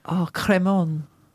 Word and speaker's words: Crémant: How do you say Cremon in French? Do your accent. – You're saying it really well.